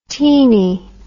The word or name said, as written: teenie